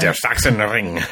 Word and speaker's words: Sachsenring: How do you say that again? – Der Sachsenring. – Right.